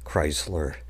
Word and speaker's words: Kreisler: and that's spelled K-R-E-I-S, Kreisler.